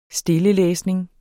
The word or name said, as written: stillelæsning